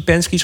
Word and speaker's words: Penske: Volgens mij het laatste vier wedstrijden hebben we nou drie Penske's gewonnen. Zeg ik dat goed? Ja.